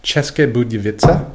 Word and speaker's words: České Budějovice: České Budějovice.